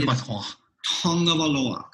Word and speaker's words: Tagovailoa: That is actually Tagovailoa. That is the correct pronunciation of that name.